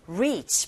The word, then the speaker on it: reach: reach, 발음하실 때 reach, 짧게 발음하지 않고 길게.